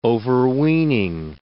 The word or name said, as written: overweening